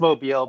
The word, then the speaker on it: Mobile: Yeah, it's Mobile, but yeah, obviously the name is a nice little pun, Mobile Homes.